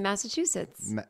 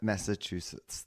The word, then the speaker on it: Massachusetts: I don't even really know how to say it. – You just say Massachusetts. – Massachusetts. – Yeah, there you go.